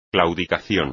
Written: claudicación

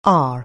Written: r

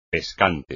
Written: pescante

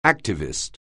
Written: activist

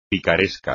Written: picaresco

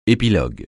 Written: épilogue

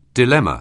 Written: dilemma

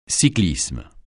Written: cyclisme

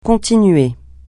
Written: continuer

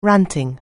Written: ranting

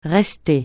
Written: rester

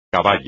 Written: caballo

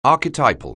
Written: archetypal

